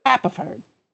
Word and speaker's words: Haverford: It's Tom Haverford, right? – Haverford.